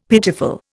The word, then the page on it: pitiful: Indian